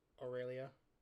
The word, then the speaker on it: Aurelia: Aurelia.